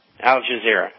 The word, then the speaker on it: Al Jazeera: Al Jazeera.